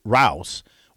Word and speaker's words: Rouse: Rouse will, rhymes with house, there we go.